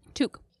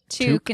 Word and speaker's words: toque: Toque. Toque instead of toque. – Yeah. – Like somebody said, somebody made that two syllables. I don't remember who, but somebody in the chat was saying that they had always pronounced it like toque or something. – Well, I think it was Amanda that said it's like toucan without the an.